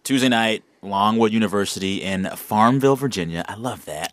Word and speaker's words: Farmville: You kind of got to say it like Louisville. – Farmville. – That's right.